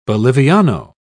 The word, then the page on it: boliviano: British English